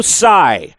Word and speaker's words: Tsai: All of which comes as Joe Tsai. Joe Tsai, that is spelled T-S-A-I,